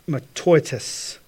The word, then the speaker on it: mataiotes: I will spell it for you, M-A-T-A-I-O-T-E-S, matoitis was the word. Jerome, sometime in 342 to 347 A.D., then translated the Greek, or the Septuagint, into Latin, which took that word, matoitis, or empty, and translated it to vanitas, or vanity.